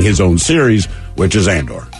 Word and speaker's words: Andor: I believe it's Andor. – Okay, all right. – I might be saying it wrong. – Andor, it looks like Candor, it's more in a city.